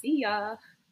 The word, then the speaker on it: Seeyah: So the way that you say it in Chinese is Su Na, but the way that you spell it is S-E-E-Y-A-H. So it's Sia.